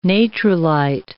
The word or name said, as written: natrolite